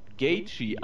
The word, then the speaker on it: Gaethje: Melvin Giller tritt um die in, im Lightweight bout um die Lightweight Championship gegen den Titelträger Justin Gaethje an, so will ich ihn mal aussprechen. Kann sogar richtig. Bitte? Ist sogar richtig so.